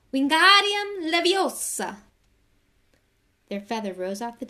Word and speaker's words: Wingardium Leviosa: Wingardium Levosia, he shouted, waving his long arms like a windmill. You're saying it wrong, Harry heard Hermione snap. It's Wingardium Leviosa. Make the gar nice and long.